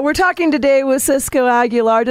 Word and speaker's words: Aguilar: Did I pronounce it right? I don't want to. Everybody messes that up all the time. Did I say it properly, Cisco? – Absolutely. I mean, obviously you can say Aguilar, but if you want to say it.